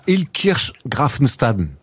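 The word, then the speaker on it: Illkirch-Graffenstaden: Ouais, je joue à Ilkirch Grafenstaden. – Très bien prononcé, hein, c'est pas toujours évident.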